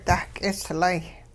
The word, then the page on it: dah-k'islay: hupa